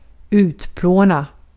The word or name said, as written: utplåna